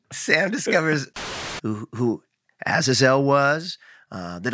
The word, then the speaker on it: Azazel: He's usually like a zazazzle. – Azazel. I think it's an Azazel.